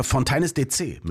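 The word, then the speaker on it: Fontaines D.C.: Und du hast es wirklich falsch ausgesprochen, ja, weil es ist Fontaines DC mit Marianne.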